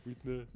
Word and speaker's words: Whitney: Parce que en, anglais, hein, d'où mon prénom vient, pays on sait bien. – Whitney. Et moi, je sais pas, je prononce comment on me l'a appris. Whitney.